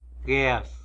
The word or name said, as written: guez